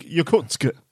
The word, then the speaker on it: Yakutsk: You just have to say it like Yakutsk.